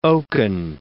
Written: oaken

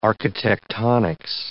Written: architectonics

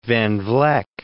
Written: Van Vleck

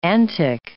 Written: antic

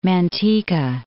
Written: Manteca

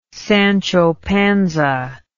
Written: Sancho Panza

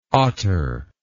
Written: ottar

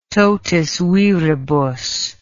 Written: totis viribus

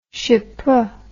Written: chypre

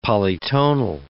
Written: polytonal

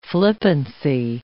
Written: flippancy

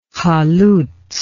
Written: halutz

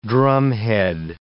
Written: drumhead

